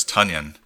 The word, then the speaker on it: Tonyan: Well, you must have missed the news because it's Tonyan. Apparently he has corrected the press after in his third season. The pronunciation from his last name is Tonyan. So like onion.